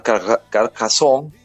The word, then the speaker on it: Carcassonne: Me fui primero al sur de Francia, a una ciudad que se llama Carcassonne, así pronuncian ellos, que es la ciudad medieval mejor conservada de Europa. – Carcassonne.